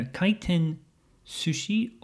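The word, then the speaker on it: kaiten sushi (回転寿司): And the sushi is usually pronounced sushi, kaiten sushi, rather than sushi. So the S changes to a Z sound.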